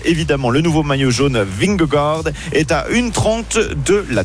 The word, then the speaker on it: Vingegaard: Vingegaard. – Oui, tout à fait. Vingegaard.